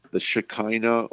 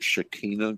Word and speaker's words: Shekinah: Well, you know, the Old Testament particularly speaks about the Shekinah, or some pronounce it Shekinah, glory of God.